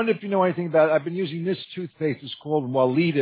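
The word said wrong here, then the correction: Weleda: It's from Germany. It's been around 100 years, and it's not commercial. – Good. That's pronounced Valeda.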